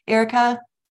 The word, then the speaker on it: Erica: Because that's one thing, like Erica, easy peasy, E-R-I-C-A, you know, with a C, that's the right way to spell it.